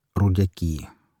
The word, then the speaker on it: Рудьяки: Во второй половине XIX века эта деревня называлась Рудьяки, ударение на последний слог.